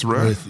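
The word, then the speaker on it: Tiamat's Wrath: How do you say it? – Tiamat's Wrath. – Yep.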